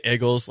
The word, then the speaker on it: Eagles: They generally say Eagles, like I-G-G-L-E-S. I don't know why they pronounce it the way that they do.